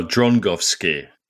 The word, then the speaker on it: Drągowski: Yeah, the keeper who was sent off by his name is Dragovsky, which Sasha Gorinov thought was particularly appropriate. – Well, Drągowski is how you pronounce it in Polish. Drągowski.